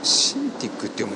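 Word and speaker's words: Cintiq: ワコム液晶タブレットシンティックって読むんですね、これ。シンティックに新製品、 24HD タッチと 22HD というニュースがございました。ワコムが液晶ペンタブレットシンティックの新製品として、 24.1 インチでマルチタッチ操作が可能なシンティック 24HD タッチと 21.5 インチでフル HD ワイドのシンティック 22HD の 2 機種が登場と。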